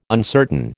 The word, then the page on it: uncertain: American English